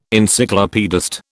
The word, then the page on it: Encyclopaedist: British English